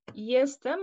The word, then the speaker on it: Jestem: C'est un nom intéressant puisque ça se prononce comme Jestem. Le mot en polonais, ça veut dire je suis, mais l'orthographe est un peu différente.